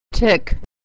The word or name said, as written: tick